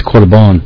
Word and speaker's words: Korban: No, I'm speaking of, I think it's Korban, or korban, as it's pronounced.